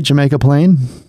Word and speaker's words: Jamaica Plain: Did I say it right? Jamaica Plain? – Yeah. Yeah.